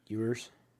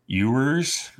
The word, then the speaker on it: Ewers: Ewers. – Ewers. Ewers like a Y. Like yours beer is my beer. You or yours.